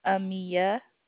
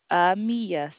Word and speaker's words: Amia: Amiya. – Pardon? – Amiya, sir. – Can you spell that, please? – A-M-I-A. – A-M-I-A? – That is correct, sir.